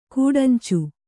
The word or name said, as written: ಗಡಂಚಿ